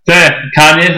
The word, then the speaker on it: Kanye: Kanye sounds a lot like Kenya.